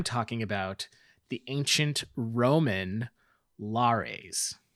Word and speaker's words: Lares: So the Lares, now that's it's Latin. So a single one would be a lar. Some of the sources I read said that they were pronounced Larrys.